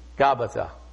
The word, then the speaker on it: Gabbatha: Gabbatha.